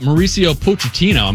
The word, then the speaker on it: Mauricio Pochettino: All right, first up, Mauricio Pochettino.